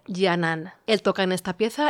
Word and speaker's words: Canan: Y la cantaba Serdar Yanan, este cantante realmente excepcional, cuyo nombre se escribe Serdar, como suena, y Yanan es con C, Canan, y es una grabación que nos ha hecho llegar él mismo.